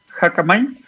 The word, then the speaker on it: Hackamine: Hackmain se escribe H-A-C-K-A-M-I-N-E, Hackamind eh, y se pueden inscribir hasta el 23 de octubre.